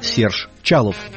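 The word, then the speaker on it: Chaloff: Харальда Арлина, настоящее имя, еще один потомок иммигрантов Хаим Арлук, баритон-саксофон Сержа Халова. Его имя и американцы, и французы произносят как Серж Чалов.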